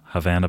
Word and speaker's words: Havana: That's the one who rhymes with banana. – Havana banana.